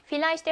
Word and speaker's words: Vielleicht: 자, 그러면 Vielleicht V는 F 발음을 냅니다.